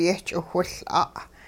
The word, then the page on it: yehch'iwhiL'a': hupa